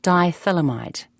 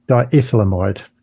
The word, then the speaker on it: diethylamide: lysergic acid diethylamide, LSD. – Diethylamide. – Diethylamide. – Yes, yes. – Lysergic, yes. Can you say it? – Lysergic acid diethylamide, or LSD for short.